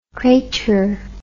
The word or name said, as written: cratur